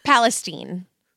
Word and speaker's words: Palestine: So it's Palestine, Texas, but they pronounce it Palestine in Texas, but it's spelled Palestine, comma Texas.